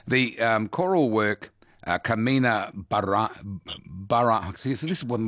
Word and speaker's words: Carmina Burana: Carmina Burana.